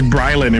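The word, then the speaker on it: BriLan: Is it Breelon or is it Breelan? Is it? – It's Breelon Imagery.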